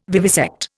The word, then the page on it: vivisect: Indian